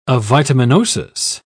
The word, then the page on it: avitaminosis: British English